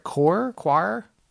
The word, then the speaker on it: coir: I don't know if I'm saying this right, coir? – Yes. – C-O-I-R.